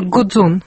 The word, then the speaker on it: Гудзон: То есть то, что в слове «гудзон» ударение на последний слог, вы считаете, что это влияние французского?